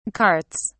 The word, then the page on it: carts: British English